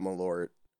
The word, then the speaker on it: Malort: We got there, and I brought Malort.